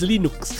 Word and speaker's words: Linux: Torvalds, and I pronounce Linux as Linux.